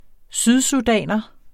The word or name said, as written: sydsudaner